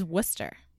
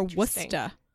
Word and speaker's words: Worcester: But I think how people who live in the town called that pronounce it is Worcester. – Oh. – Or Worcester.